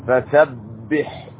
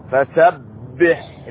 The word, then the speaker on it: فسبح: فسبح، اكسر الباء جيدا مع شدها، فسبح بحمد ربك واستغفره.